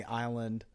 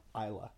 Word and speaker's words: Islay: It's spelled I-S-L-A-Y, which I always used to think was Islay. But it's actually pronounced Isla, according to Wikipedia. – Okay. – And, you know, Wikipedia is always right. – So it's like island without the and.